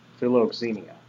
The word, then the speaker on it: philoxenia: The word entertain strangers is actually a compound word in the Greek, and it's philoxenia, or philoxenia. There we go, be the proper pronunciation.